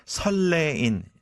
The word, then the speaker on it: 설레인: Let's move on to our second In Discover artist, 설레인, 싱어송라이터 설레인. 영어 이제 스펠링이 S-E-O-L-R-E-I-N, 설레인 이렇게.